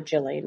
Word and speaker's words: Ghislaine: Some people say Ghislaine, some people say Ghislaine, but it's definitely G at the beginning.